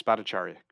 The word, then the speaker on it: Bhattacharya: That starting in 1979, Sudipta Chattoria, I butchered the name. – I know University of Chicago. Do you know the proper name of that person in 1979? – I don't remember exactly the pronunciation. Yeah. Bhattacharya, maybe? – Yes, Bhattacharya. Very good.